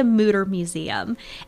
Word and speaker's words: Mütter Museum: It's called we argue over how you say it. I say the Mütter Museum.